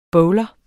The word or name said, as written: BOWLER